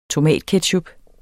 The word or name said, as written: tomatketchup